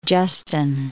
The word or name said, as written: Justin